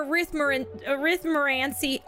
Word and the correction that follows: Arithmancy: How do you say that word? Arithmancy? I got it.